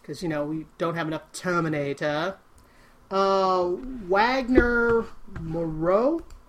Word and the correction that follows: Moura: I'm saying his name wrong, Moura, has been tapped to star opposite Elizabeth Moss in Shining Girls at Apple TV.